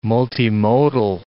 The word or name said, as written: multimodal